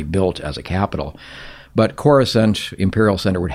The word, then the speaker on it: Coruscant: Dictionary pronunciation is a little bit different, but the spelling of the word supports Coruscant as a name.